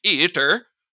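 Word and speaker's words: Eitr: I have stamina, health, and how do I pronounce that? Eater? Eater. – Yeah. – It is a Scandinavian game, after all.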